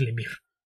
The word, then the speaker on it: Lemire: Pero la correcta es Lemire.